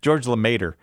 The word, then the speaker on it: Lemaître: George Lemaître is the way you say it in English.